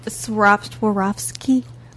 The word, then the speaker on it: Swarovski: What it is it's Swarovski crystals.